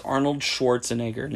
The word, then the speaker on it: Arnold Schwarzenegger: I could add his name is pronounced Arnold Schwarzenegger, just so you know.